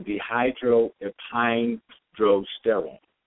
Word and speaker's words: dehydroepiandrosterone: That's pronounced dehydroepiandrosterone, or more commonly called DHEA, dehydroepiandrosterone.